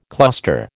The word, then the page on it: cluster: American English